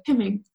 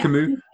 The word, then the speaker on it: Camus: It's spelled Camus. It's Camus, I think you said? – Camus, yeah. – Camus, perfect.